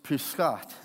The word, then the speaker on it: Pesach: Tomorrow is Pesach, is how you say it in Hebrew.